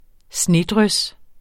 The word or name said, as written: snedrys